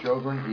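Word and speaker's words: Sjogren: Jerry Shogren, E.L.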